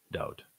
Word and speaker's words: Dovid: Nothing Yahweh revealed has been annulled, especially his love for his firstborn son, Dovid. And sadly, Jews couldn't even get his name right. It's not David. There is no V in the Hebrew alphabet, at least the alphabet that was used at this time.